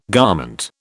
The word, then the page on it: garment: British English